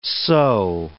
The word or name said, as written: so